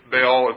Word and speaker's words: Baal: So the word, we often say it Baal, B-A-A-L, probably something pronounced closer to Baal, or even Baal kind of,